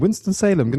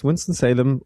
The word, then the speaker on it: Winston-Salem: Winston-Salem, genau richtig. Gab schon die unterschiedlichsten Varianten. Es ist nicht Salem, es ist nicht Winston, aber es ist Winston-Salem und hier bin ich jetzt zu Hause.